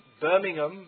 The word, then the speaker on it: Birmingham: In Birmingham, you say in civilized English, BBC English. And in Birmingham, they say Birmingham. They pronounce it like that.